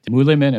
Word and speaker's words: Mühlemann: Is it Mühlemann and Popp? Is that? Yeah, that's correct. Yeah. – Difficult to pronounce for a non German speaker. Oh, I think I did okay. But uh, Absol- very good. Very good.